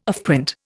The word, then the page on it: offprint: Indian